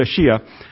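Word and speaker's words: Schia: It's S-C-H-I-A, pronounced Shia.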